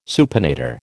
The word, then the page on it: supinator: American English